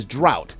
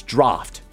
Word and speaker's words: draught: It's an unusual spelling that doesn't reflect its pronunciation, particularly with the silent G-H. A common mispronunciation that you might hear is drought or draft. It's draft.